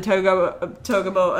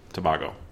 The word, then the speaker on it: Tobago: And also Trinidad and Togo assistant. – Tobago.